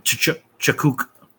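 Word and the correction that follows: Tkachuk: There's no T. It's just Kachuk. – There's a T right there. Oh, I see what you mean. The T is silent.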